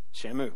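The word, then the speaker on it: Shammah: Then Jesse made Shammu pass by. That's how you say his name, Shammu.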